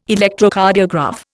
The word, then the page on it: Electrocardiograph: Indian